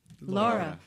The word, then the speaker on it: Laura: Laura is L-A-U-R-A. – Laura. Yeah. In America, we say Laura.